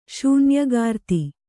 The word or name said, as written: ಶೂನ್ಯಗಾರ್ತಿ